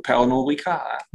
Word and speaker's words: Pernod Ricard: Und da gab es erst einmal sehr, sehr gute Zahlen von Pernod Ricard. Habe ich es richtig ausgesprochen? – Ich glaube schon, ja. Das wird Französ ein Franzose wird wahrscheinlich Französisch ausgesprochen. Pernod Ricard.